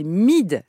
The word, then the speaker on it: Myd: Mid. Ça s'écrit M-Y-D et ça sonne comme ça.